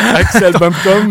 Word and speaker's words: Axel Bampton: Alors, Axel Bampton, bah, évidemment, c'est très british, puisqu'il y a le P qu'il faut prononcer.